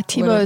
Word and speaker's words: Thibaut: Yeah, Tebow is Thibaut. Mm-hmm. It sounds different than it's spelled.